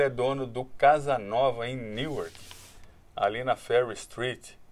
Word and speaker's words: Newark: A pronúncia correta é Newark.